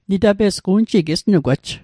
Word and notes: unji: un'ji; Mi'gmaq

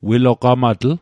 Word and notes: wilogamatl: Mi'gmaq

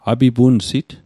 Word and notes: apipunsit: Mi'gmaq

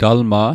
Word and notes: talma': Mi'gmaq

